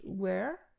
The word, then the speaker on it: were: Je précise ici la prononciation de were, W-E-R-E, W-E-R-E. E. Were est le pluriel de was. Faites bien attention à la prononciation de were il ne se prononce pas comme OU, euh, où, comme where pour s'enquérir d'un lieu. Où habites-tu? Where do you live? Mais were, c'est le pluriel de was.